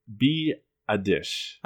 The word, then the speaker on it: Biadasz: I am looking at, in the fourth round, the Eagles taking Wisconsin center Tyler Biadasz. – I like that you pronounced that correctly. – Yes. Phonetically. I looked it up.